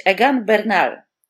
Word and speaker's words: Egan Bernal: Uwaga, poprawnie należałoby powiedzieć Egan Bernal, ale okej, okay. z akcentem. Tak. tak. Y, tak, ale dobra, mówmy Egan Bernal.